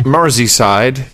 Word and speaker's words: Merseyside: Sir Justin Seitz in Pittsburgh, Sir Keith Edwards in Gilbert, Arizona, Sir Lawrence McBride in Moreton, Merseyside. Is that how you pronounce that, by the way? – Merseyside. Yes, Merseyside.